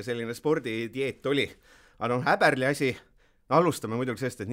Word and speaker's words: Häberli: siis see mees paar päeva tagasi ütles, et kui on ikkagi Sveitsis, tema nime öeldakse Toomas Häberli ääga, ehk siis jääme selle juurde, aga mis kõige olulisem, tegelikult jaanuari alguses siis mees ametlikult välja kuulutati, kuigi kuu aega varemaid sahinad.